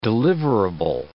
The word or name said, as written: deliverable